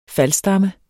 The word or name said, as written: FALDSTAMME